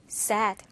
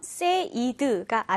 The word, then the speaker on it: said: 그래서 pay, paid, paid, aid 이렇게 발음이 되는데 특이하게 say 같은 경우에는요, say 같은 경우에 이 발음이 sad, sad로 발음이 됩니다. 자, paid처럼 said가 아니라 set로 발음된다라는 거꼭 기억하면 좋겠습니다.